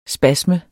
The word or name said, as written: spasme